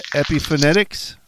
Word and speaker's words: Epiphonetics: Your debut album, I believe I've got the name correct, Epiphonetics. Did I say that right? – Yeah, that's right.